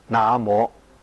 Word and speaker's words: 南无: 咁在梵语里面称为那摩，当时就翻成南无这两个音。我们的语言是会随着时代变的，字的形状没有变，但是读音古今就不一样，所以应该要读古音，读成那摩。